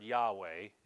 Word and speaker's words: Yahweh: So instead of, we would pronounce that word Yahweh, if you've ever heard that. The older pronunciation for that word is Jehovah. Both of those words are the same word.